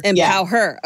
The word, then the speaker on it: Empower Her: So let's get into some of your conferences, because, you know, certainly I know Empower is, and am I saying that right? Because I know it's emphasis on her, but. – Yeah. Empower her.